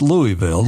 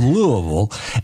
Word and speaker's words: Louisville: You got to say that right, not Louisville, Louisville.